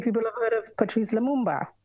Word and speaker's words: Lumumba: So this January, we look at Patrice Lumumba.